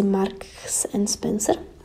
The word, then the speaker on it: Marks & Spencer: Tak piju teda organický čaj z Mark & Spencer a já to nikdy neumím vyslovit, protože my to doma vždycky s mamkou zkolomolíme. Tak je to asi Marks & Spencer. Mělo by to tak být.